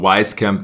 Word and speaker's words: Wieskamp: Ja, dann haben sie noch Joe Wieskamp in der zweiten Runde in 41 oder Weiskamp, Weiskamp. Äh, wird der amerikanisch mhm. ausgesprochen. Sieht sehr deutsch aus, der Name. Joe Weiskamp dann wohl äh, an 41.